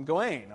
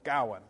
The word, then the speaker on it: Gawain: But here, this is alliterative verse, and the emphasis is on the first syllable, Gawain. He's clearly not Gawain, because the emphasis, the alliteration, is usually on the G, so that's what's clearly supposed to be emphasized.